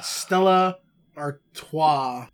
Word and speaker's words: Stella Artois: I'm drinking Stella Artois.